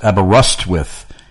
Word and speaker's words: Aberystwyth: He said, Aberystwyth. He said, remember rust. That's what the trick was.